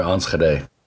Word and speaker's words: Enschede: But I mostly lived on the east end of Nederland in an area near Enschede. – Wow, that's perfect pronunciation, too.